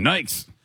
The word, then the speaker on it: Nike: Originally, we always said Nikes. – Mm-hmm. – That's true. We didn't know it was Nike. – That's true. – Get some Nikes.